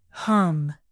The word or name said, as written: hum